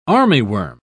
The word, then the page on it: armyworm: British English